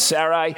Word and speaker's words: Sarai: That's how it's pronounced in the Hebrew, in the Jewish culture, Abram and Sarai.